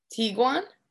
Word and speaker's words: Tiguan: Tiguan? – Yeah.